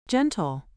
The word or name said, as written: gentle